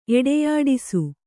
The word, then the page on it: ಎಡೆಯಾಡಿಸು: Kannada